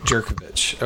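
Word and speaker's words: Jurkovec: Jerkovich. Okay. Well, that's interesting. They pronounced the J.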